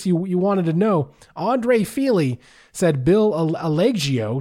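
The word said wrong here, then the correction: Algeo: Algio? – Algio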